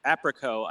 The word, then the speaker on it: Aprico: Yeah, so it's Aprico. I say it's like apricot without the T, basically.